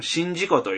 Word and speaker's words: 宍道湖: 市畑電車って、あの、その、例の、何でしたっけ、スイッチバックの、平面のスイッチバッチのバックのあるところですけど、まあ、その横をずっと車で走りながら、その、日本で、まあ、面積としては 7 番目に大きいと言われてる、この、新事故という。 – しじみで有名なところ、ね。 – はい。これ、新事って読むんですよ、これ。